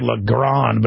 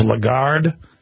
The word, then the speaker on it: Lagarde: I always say Lagarde, but it's Lagarde.